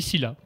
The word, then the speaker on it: Scylla: Ah, on dit Scylla, pour syllabe.